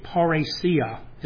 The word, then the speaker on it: parrhesia: There was a word in Greek, parrhesia, it's pronounced, which means bold speech.